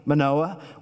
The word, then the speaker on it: Manoah: So we meet Samson's father, Manoah, that's how to pronounce his name, Manoah.